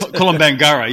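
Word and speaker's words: Kolombangara: Is it? Columbangara, yes.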